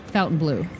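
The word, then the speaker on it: Fontainebleau: Technically, you're supposed to say it Fountain Blue. – According to Fountain Blue, that's how you're supposed to pronounce it.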